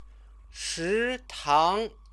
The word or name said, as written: shitang